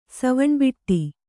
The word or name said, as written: ಸವಣ್ಬಿಟ್ಟಿ